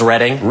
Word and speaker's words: Reading: If you go to this seat of Berks County, Pennsylvania, remember to say the first syllable like the color. – What is Reading? – Reading, right you are.